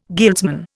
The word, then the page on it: guildsman: Indian